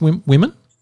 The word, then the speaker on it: womxn: They're advocating the change and the new spelling for women is W-O-M-X-N because they're trying to remove the men from it. So it's still pronounced women, but the spelling of it is they are now spelling it as W-O-M-X-N.